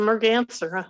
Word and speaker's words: merganser: It's called what, a common merganser? Am I pronouncing it right? – That's right. It's a merganser, huh? – Merganser.